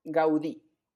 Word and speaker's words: Gaudí: Y para empezar, y porque siento que me voy a equivocar mucho durante el episodio, Antonio, Antoni, Gaudí o Gaudí. ¿Qué es lo correcto? – Antoni Gaudí. Acento en la I.